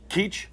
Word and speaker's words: Keech: Jeff, and how do you say your last name? Keech? – Keech. – Keech. – Oh, okay. It's been pronounced a number of ways, you can imagine.